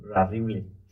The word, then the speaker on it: Rarible: Rarible.